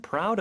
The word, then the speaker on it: proud: P 발음은 P가 아니에요. P 요런 소리예요. 그래서 loud, R 발음으로 연습해 주신 다음에 proud 이렇게 살짝 넣는 연습해 주시면 될것 같아요. Proud, proud. 그래서 P하고 R 하나의 자음처럼 들려야 합니다.